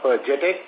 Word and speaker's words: JTEKT: Now let me clarify, like, it is not JTEKT, it is JTEK. The word is, it is single word which is pronounced as JTEK.